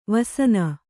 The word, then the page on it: ವೆಸನ: vesana